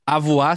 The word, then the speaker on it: Àvuà: Então, a gente postou lá no Instagram, tem mais discos, arroba TMDQA, e você pode ver no YouTube, procura Avoar, se escreve A-V-U-A, e tem crase nos dois As, Avoar, é, Dois Sóis, o nome da música.